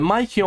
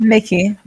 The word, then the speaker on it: Mickey: Is it Mikey or Mickey? It's Mickey, right? – Mickey. Okay, like good. Mickey Mouse.